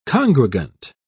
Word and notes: congregant: British English

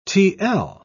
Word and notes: tl: British English

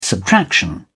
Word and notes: subtraction: British English

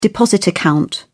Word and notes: deposit account: British English

